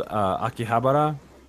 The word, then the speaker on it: Akihabara: Yeah, there's one here in Denver that's called Akihabara, right? That's like the arcade. – That's a cool one. You had a pretty good pronunciation, too. – Akihabara.